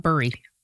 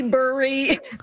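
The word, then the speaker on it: Bury: And by the way, if you're looking at it on the map, it's B-U-R-Y. It's pronounced Barrie, right? Not Burry. – Burry. – Okay, Burry, so you accept both? Okay. – It's pronounced, I mean, I say Burry.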